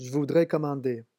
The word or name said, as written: je voudrais commander